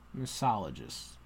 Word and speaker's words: misologists: Now, what's sad is the misologists. You don't pronounce that first O? Misologists? – You're doing an extra O. – No, I'm doing the first O. Misologists. Oh, wait. Oh, I'm not. I'm making the L a different syllable. Okay. Misologists. Fine.